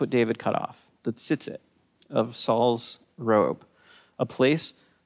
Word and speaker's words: tzitzit: Now, this little piece of fabric is called the tzitzit. The tzitzit, it's hard to say in Hebrew, but I would spell it T-Z-I-T-Z-I-T, tzitzit.